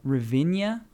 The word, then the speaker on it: Ravinia: Pavilion seats at Ravinia. – Oh. – Did I say that right? – Yeah, that's correct.